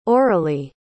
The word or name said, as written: auralie